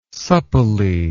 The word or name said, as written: supplely